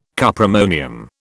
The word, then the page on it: cuprammonium: British English